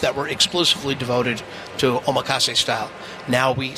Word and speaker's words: omakase: Omakase.